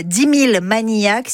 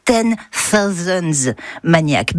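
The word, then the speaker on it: 10,000 Maniacs: Alors le titre que tout le monde prononcerait ainsi, 10 000 maniaques, si vous voulez frimer, ça se prononce 10 thousands maniacs, because the night.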